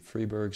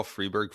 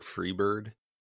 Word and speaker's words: Freiburg: Do people actually call Freiburg Freebird? – No. They don't.